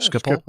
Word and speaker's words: Schiphol: Schiphol. All of a sudden, I'm thinking I'm not pronouncing that correctly. – No, Schiphol? Schiphol is the way I always pronounced it.